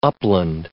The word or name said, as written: upland